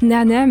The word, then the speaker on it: Na3Na3: Ça se prononce Nana, mais moi aussi j'ai un accent français donc euh, je ne te jugerai pas Swazik.